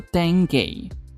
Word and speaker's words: dengue: 据说啊是十九世纪才开始被广泛运用，而登革的英文念作 dengue，据说是来自于非洲的史瓦希利语，意思是抽搐、癫痫发作的状态。